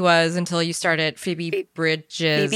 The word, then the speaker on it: Bridgers: Bridgers. Okay. I didn't know if the R was silent or not. – Why would the R be? – Like, you know how, because in the British accent, the R is silent sometimes.